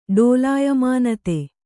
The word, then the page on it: ಡೋಲಾಯಮಾನತೆ: kannada; dōlāyamānate